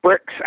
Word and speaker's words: BRICS: It's BRICS, actually, is the proper pronunciation of it.